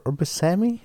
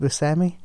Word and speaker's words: Buscemi: I know that Steve Buscemi shows up at some point, or Buscemi. Somebody told me it's actually pronounced Buscemi, and that blew my mind because I've been pronouncing it Buscemi my entire life. So I'll start pronouncing it correctly.